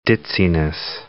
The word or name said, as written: ditziness